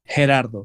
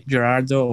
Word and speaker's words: Gerardo: Is it with G or with the, like, Gerardo? – Okay, it's here is Gerardo, G, like Gerardo. – Yeah, exactly. – Okay, because I heard a lot of people mispronounce it. Me myself, I used to say it like Gerardo. – Well, it sounds cool for me, Gerardo, or you can call me just Gero.